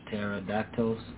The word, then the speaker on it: pterodactyl: Teractatil. Teractatil. Teractatil. I can't pronounce that word. Mm-hmm. What, what those flying birds are called. – Ah, yeah, they're from the past. – Pterodactyls. – pterodactyl, yeah, pterodactyls.